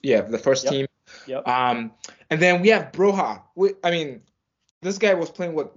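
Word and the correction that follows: Broja: Actually pronounced Broya, but yes.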